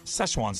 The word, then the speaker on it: Szechuan: I guess this is pronounced Szechuan sauce. – It's Szechuan. Been gone. – Szechuan sauce. – Yeah, Szechuan. – Szechuan.